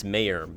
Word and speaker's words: Mare: She's the mayor actually of that town. M-A-R-E. But she would pronounce mayor. That is the accent.